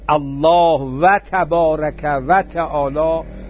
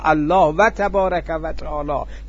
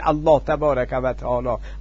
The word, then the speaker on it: الله و تبارک و تعالی: اجازه میفرمایید من عرض کنم وقتی شما میگید الله و تبارک و تعالی، غلط تلفظ نمی کنم، هر دو رو با واو میارم، دو تا اسمه، و تبارک و تعالی، الله و تبارک و تعالی، که به غلط متاسفانه میگن الله تبارک و تعالی، الله و تبارک و تعالی.